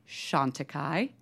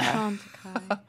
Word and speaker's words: Chantecaille: She went on to create Prescriptives and eventually her own line, Chantecaille. How's my pronunciation? – Very good, very good. – I know. Our French isn't great here at Fat Mascara. – Chantecaille. – Chantecaille.